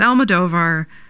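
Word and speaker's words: Almodóvar: It's Almodovar, but sometimes my mouth wants to do something else.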